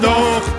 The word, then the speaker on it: Doch: Wie schön war doch die Junggesellenzeit. – Du, hur uttalar du Dusch? Doch, säger jag. Doch, långt bak.